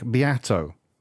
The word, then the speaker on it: Beato: A YouTube channel with a guy called Rick Beato. It's spelled B-E-A-T-O, Beato, Rick Beato.